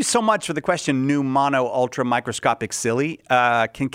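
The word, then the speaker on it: pneumonoultramicroscopicsili: I'm going to assume that PN in English is pronounced with a silent P. – Yes. – So I believe that this is new mono ultra microscopic silly.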